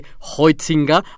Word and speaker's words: Huizinga: Lleva un apellido que se escribe Huizinga, con H y con Z pero dependiendo el ambiente académico en el que esté, puede pronunciarse con más o menos afectación, desde Hoitzinga hasta el más afectado Hoitzinga.